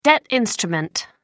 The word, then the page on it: debt instrument: American English